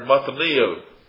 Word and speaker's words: metanoia: Repent is the Greek word metanoia, or metanoia. Metanoia, I think, is a better pronunciation.